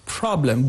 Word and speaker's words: problem: If you take the word like problem in English, the first syllable has a stress, problem.